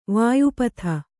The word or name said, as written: ವ್ಯಾಪ್ತ